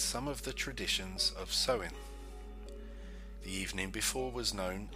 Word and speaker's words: Samhain: It's thought to have originated with the ancient Celtic festival of, pronounced Samhain, Samhain, okay, but pronounced Samhain, when people would light bonfires and wear costumes to ward off the roaming ghosts.